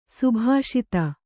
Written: ಸಭಾಸದ

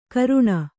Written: ಕರೇಣು

